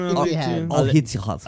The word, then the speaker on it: Al-Ittihad: How do you pronounce that name? – Al-Ittihad. – There you go.